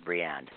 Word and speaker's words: Briand: Tell us a little bit about this before she meets Jimmy Bryant. – Oh, that's interesting. Well, all right. Jimmy is Briand, is how you pronounce it, Briand.